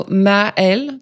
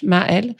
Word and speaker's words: Maëlle: So Maëlle, the first name, Ma like mom, and then Elle like she in French, Maëlle.